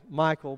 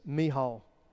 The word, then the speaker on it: Michal: As the Ark of the Lord was entering the city of David, some of you will probably pronounce this name Michal, but actually, technically, it's Michal, daughter of Saul.